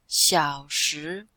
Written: xiao3shi2

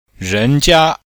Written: ren2jia1

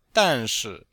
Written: dan4shi4

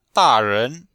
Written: da4ren2

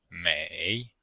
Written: mei3

